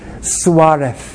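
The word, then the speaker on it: Suárez: If you're precise about your Spanish, Suarez. Yes, it's this Castilian Spanish, so you have to say it like it's a TH.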